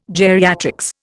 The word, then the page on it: geriatrics: Indian